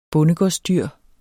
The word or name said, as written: bondegårdsdyr